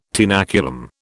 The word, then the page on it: tenaculum: British English